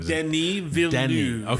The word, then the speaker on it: Denis Villeneuve: Denis Villeneuve.